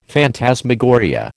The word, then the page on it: phantasmagoria: American English